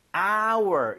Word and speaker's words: hour: 이게 hour 묵음이 아니라 예. 네. – hour 이렇게 발음이 되는 거예요. – 아. – 발음 자체가 모음 사운드예요.